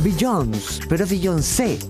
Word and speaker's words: Beyoncé: Por mucho tiempo yo estaba convencido de que la forma correcta de decir el nombre de esta artista era Beyoncé.